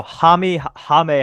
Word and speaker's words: Jaime: My pick is going to be Jimmy Jaquez Jr. Obviously. – At least say his name right. – What is it, Jaquez? – Jaquez. – Jamie Jaquez Jr.? – No. Jaime Jaquez Jr. Oh my gosh, dude.